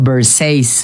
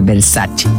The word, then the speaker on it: Versace: Por ejemplo, Versace, cuando la marca Versace es italiana, entonces tiene que pronunciar Versace.